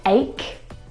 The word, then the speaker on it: ache: And there are quite a few English words that actually have this same pronunciation of the CH combination. Words like stomach. – Stomach. – And ache. The CH in all of these words is pronounced like a K sound.